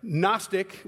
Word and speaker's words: Gnostic: It's the same word, if you think about it, Gnostic with a G in front of it is the same way of us saying knowledge with a K in front of it. We just have a different silent letter.